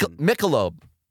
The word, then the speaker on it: Michelob: I laughed because you said Michael. – And then like a male's name. – You weren't confident in your own pronunciation. – It's Michelob. But you said Michael.